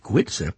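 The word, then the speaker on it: Goitse: And after that, the song The Green Fields of Canada, the band, Irish band Gwitza, which I think basically is kind of an informal hello or greeting or come here.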